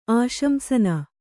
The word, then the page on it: ಆಶಂಸನ: āśamsana